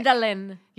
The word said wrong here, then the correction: mandelic: Is that how you say it? Mandelic. Mandelic. – Mandelic. That's the apple one.